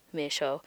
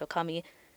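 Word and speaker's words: okami (女将): So when Momiji says it, he reads it as me sho, which is not correct. Often when you combine kanji together, they have certain pronunciations when they're combined in a certain way. And in this case, it should be pronounced okami.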